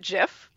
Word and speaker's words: GIF: because the inventor of the word GIF pronounces it GIF and has been very clear, like the peanut butter.